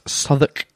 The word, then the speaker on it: Southwark: Southwark is spelt Southwark.